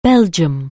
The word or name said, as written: Belgium